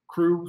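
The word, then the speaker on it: Krewe: right now go to the Google the Crew of Red Beans. Crew, spelled with a K, K-R-E-W-E, red beans.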